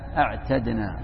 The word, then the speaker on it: أعتدنا: شف أصلها من العدة، من العدة أو العتاد، أعددنا أصلها داء ودا، لكن لما كان نطقها فيه صعوبة قلبت الدال الأولى تاء، أعتدنا أعتدنا.